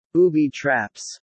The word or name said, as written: booby-traps